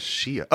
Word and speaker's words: Xia: What is Shia? Oh, XIA. Is that how you pronounce that? – Yeah, I believe so.